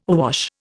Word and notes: awash: Indian

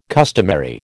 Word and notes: customary: American English

